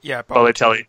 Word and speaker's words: Balotelli: I think it's Balotelli. – Balotelli.